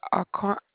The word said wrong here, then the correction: Aquatic: Drive. – Don't feel random. Aquatic. AQUATS. Aquatic Drive.